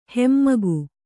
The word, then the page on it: ಹಮ್ಮುಗೆ: hammuge